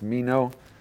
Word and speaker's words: meno: this beautiful word of abide happens to be, I don't know how to pronounce it in Greek, but it's meno, is how we'll pronounce the Greek word in English.